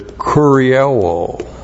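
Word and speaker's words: Kurioō (κυριεύω): The last verse of chapter 1, the word that is used is kurioō. K-U-R-I-E-U long O. Kurioō.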